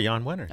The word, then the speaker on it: Jan Wenner: And Kabir, so we've got those remarks um, that Jan Wenner made Am I saying the name right? – You are Jan Wenner. Okay.